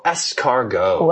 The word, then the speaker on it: escargot: Escargot.